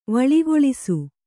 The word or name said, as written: ವಳಿಗೊಳಿಸು